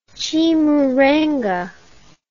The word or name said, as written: chimurenga